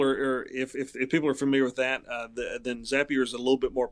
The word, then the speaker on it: Zapier: I also use a website called, I think you would pronounce it Zapier, unless they're French and it may be Zapier, but it's Zapier.